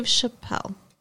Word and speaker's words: Chappelle: Dave Chappelle. – Oh, is that how it's pronounced? – Yeah. – Like it's French? – I guess.